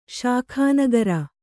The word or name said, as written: ಸಕುನಗಾರ